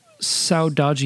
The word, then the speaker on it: saudade: You use the Portuguese word, I might mispronounce this, saudade? Is that how you say it? – Yeah, that's how I say it. – Okay. Well, if the two of us non-Portuguese speakers think it's right, then it's got to be right. – And my Brazilian friend Joaquin also pronounces it that way, so we'll go with it.